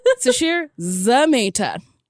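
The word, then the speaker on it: Sasheer Zamata: I said her name wrong, too. – I was like, that's a different pronunciation it than is. I've heard. – Sashir Zameta. – Perfect. – Not Zameta.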